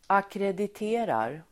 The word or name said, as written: ackrediterar